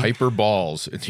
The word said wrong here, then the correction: hyperbole: Well, hyperball. – I don't know. My pronunciation might be very off. – Hyperbole. It's okay.